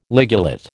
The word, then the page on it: ligulate: American English